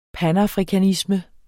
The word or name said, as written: panafrikanisme